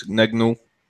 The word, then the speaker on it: Gnagnon: The young defender, Joris Gnagnon, I'm not really quite sure how to pronounce his name. – Who? – Joris Gnagnon. He's guy. We have problems pronouncing his name too. It's Gnagnon.